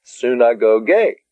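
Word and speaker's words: Synagoge (συναγωγή): The last word there is synagoge. Synagoge. The accent again is on the last syllable. Soon. Synagoge. Synagoge.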